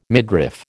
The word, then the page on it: midriff: American English